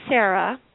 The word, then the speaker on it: Sarah: I'm Sarah. Don't pronounce the H because I will be really disrespected.